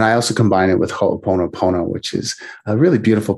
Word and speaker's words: Ho'oponopono: Ho'oponopono. I'll have to spell it for you. It's H-O apostrophe. O-P-O-N-O, P-O-N-O.